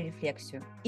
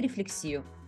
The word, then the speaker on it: рефлексию: Это второй эпизод второго сезона, в котором мы продолжаем говорить про рефлексию или рефлексию, потому что стало понятно, что эти слова вообще-то практически похожи, одинаковые, и э, люди, которые придумали это слово, пользовались книгами, а в книгах ударения не ставят.